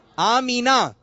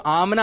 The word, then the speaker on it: آمنہ: اس کا صحیح تلفظ ہے آمینہ. آمینہ. تو جو آمنہ آمنہ کر رہے تھے، یہ آمنہ تلفظ جو ہے یہ صحیح نہیں ہے.